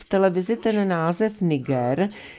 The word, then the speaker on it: Niger: je správná výslovnost s přízvukem na první slabice Niger.